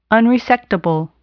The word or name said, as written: unresectable